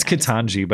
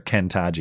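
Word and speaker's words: Ketanji: It's Kitanji, but I like calling her Kentaji because that's the Jen Psaki approved pronunciation.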